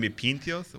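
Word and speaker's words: pintxos: It's pinchos with a lisp. – Yeah. Yeah. And isn't the ch sound an X? How you spell it. – Well, yeah, it's good spelled. Well, you had X in Catalan is a ch sound, but when you get over to Galicia, the X is actually a J sound.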